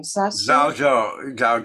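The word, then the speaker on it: Zhaozhou: Zhao Zhou. Yeah. – Zhao Zhou's dog. Someone asked Zhao Zhou, does a dog have Buddha nature or not? Zhao Zhou said, no. Working with the koan. Zhao Zhou was a minimalist.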